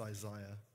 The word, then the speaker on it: Isaiah: I mean, you wanted to, like Isaiah said, it's pronounced Isaiah.